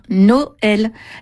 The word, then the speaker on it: Noël: Noël avec un tréma sur le « e » pour qu'on prononce bien « Noël ».